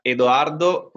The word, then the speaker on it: Eduardo: Well, in Italian is Eduardo Kirsi della Rovere. So actually, you were pretty close to it.